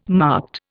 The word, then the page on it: marked: Indian